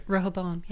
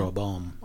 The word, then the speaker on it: Rehoboam: we're seeing like Avatar of Roa Boam. Ro- is that right? – Did I actually say it right? Rehoboam. – Yeah. Rehoboam uh, mm-hmm.